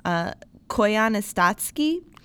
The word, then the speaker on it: Koyaanisqatsi: It's called, and I'm going to butcher this, Koyaanisqatsi. – Nailed it. – Thank you.